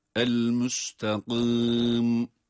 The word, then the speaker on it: el-müstakim: Burada hususiyle esre var. Kaf da kalın. Öyleyse gım diye el-müstakim diye okuyamayız. El-müstakim ince bir sesle.